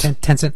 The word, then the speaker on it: Tencent: They've been supplying AI services to Alibaba, Baidu, Tencent. Am I pronouncing that right? – Yes. – Tencent.